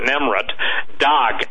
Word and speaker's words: Nemrut Dagh: And if people want to go look this up, Nemrut, N-E-M-R-U-T, some people pronounce it Nemrut, Dagh, D-A-G-H.